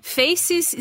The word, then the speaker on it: Feics: Faces escreve como se fala, F-E-I-C-S.